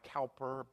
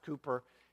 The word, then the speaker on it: Cowper: One of the great hymn writers of the faith, his name William Cowper, it looks like Cowper, but it's pronounced Cooper.